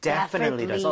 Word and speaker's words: definitely: 자, 그런데 강세를 여러분들 이쪽에 줘보는 거예요. 이렇게 하게 되면 definitely 이렇게 앞쪽에 강세가 가면서 오. – 뒤에 틀리라고 할 여유가 안 생기게 돼요. – 네. – 그렇게 돼서 definitely 이런 이상한 발음이 아니라 definitely, definitely 이렇게 발음이 될 수밖에 없습니다.